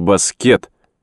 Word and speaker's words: баскет: Заторчав в жизнерадостном веселье, ребята выходили на площадку и предлагали двум дедам сразиться в баскет, то есть с ударением на последнем слоге.